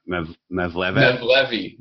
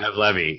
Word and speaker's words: Mevlevi: I didn't know that you were more formally involved with the, help me pronounce it right, Mevlevi. Yeah. – Mevlevi, yeah.